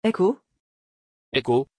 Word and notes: heyko: French

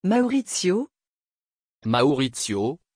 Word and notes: Maurizio: French

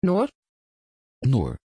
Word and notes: Noor: Dutch

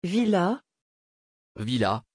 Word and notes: villads: French

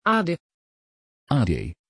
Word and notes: aden: Dutch

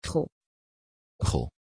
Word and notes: chael: Dutch

